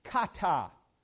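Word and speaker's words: kata (κατά): That little phrase in verse 2, according to, is a Greek word, it's a special word, and it's the word pronounced kata.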